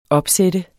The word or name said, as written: opsat